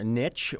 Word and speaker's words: niche: and this one's a little bit more niche or niche, depending how you like to pronounce that word.